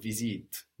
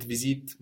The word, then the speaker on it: visite: So we don't pronounce this E on visite. No, it's there to let us know to pronounce the T. But having said that, you will hear that E on the end in some dialects of French. Visite, visite, but visite much more common.